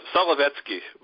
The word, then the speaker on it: Solovetsky: The Slovetsky Islands, is that how it was? – Yeah, well, pretty close. Solovetsky.